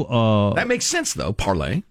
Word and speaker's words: Parler: Parler is French for to speak, pronounced parlay, as in parlez-vous français. But then this writer spells parlay, L-E-Z, as it is spelled.